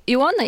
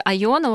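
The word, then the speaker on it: Iona: Остров Иона или Айона, вот как... если произносить по-местному, Иона у нас сложилась.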